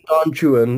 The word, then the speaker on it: Don Juan: Nope. I mean Don Juan. In this one context, it's pronounced like that. – Huh. – Otherwise, the rhymes in the poem will not work.